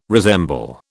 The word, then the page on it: resemble: British English